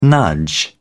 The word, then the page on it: nudge: British English